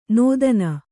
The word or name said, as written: ನೋದನ